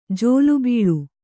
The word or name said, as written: ಜಿಲಿಬಿಲ್ಲಿ